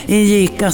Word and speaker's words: Injika: Den heter Injika. – Injika. Ja, man får uttala det hur man vill. Injika, säger jag.